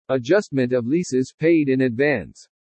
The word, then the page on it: ADJUSTMENT OF LEASES PAID IN ADVANCE: english